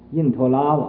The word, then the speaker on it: 因陀罗: 互相现影，影也没有穷尽，这个光也没有穷尽，下边就说了，如因陀罗网世界。这个世界呀，也就像这个因陀罗网似的，因陀拉网，这个字本来是个罗字，但是读成拉，就是因陀拉网，因为那么因陀拉也，那个楞严咒上那个因陀拉网世界，像那个世界，这个世界也就像这个因陀拉网啊，这样重重无尽。